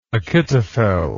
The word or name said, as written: Achitophel